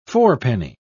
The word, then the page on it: fourpenny: British English